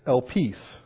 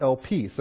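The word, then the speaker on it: elpis (ἐλπίς): And the Greek word used here is elpis. That's how you pronounce it, elpis.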